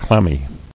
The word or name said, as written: Clamminess